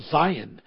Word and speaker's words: Zion: Hermon is also, according to Deuteronomy, it's also Zion, S-I-O-N, which also can be pronounced Z-I-O-N.